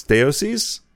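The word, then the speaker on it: Deioces: The first name is Deoces. Deoces? – Yeah.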